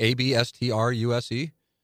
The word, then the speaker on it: abstruse: Abstruse. Is that how it's pronounced? Abstruse? – Yep, that's right.